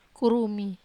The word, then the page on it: kurumi: Wamesa